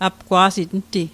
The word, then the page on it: apgwa'sit: apkwa'sit; Mi'gmaq